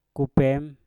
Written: kupem

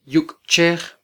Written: yukchej

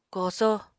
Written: koso

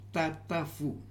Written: Tatafu

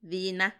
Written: dina

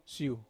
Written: siu

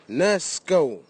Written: naskou

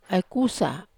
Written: ai kusa

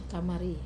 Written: kamari

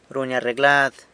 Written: runy arreglad

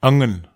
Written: agen

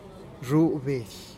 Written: zhub bedih

